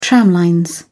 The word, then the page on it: tramlines: British English